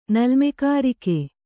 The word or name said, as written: ನಲ್ಮೆಕಾರಿಕೆ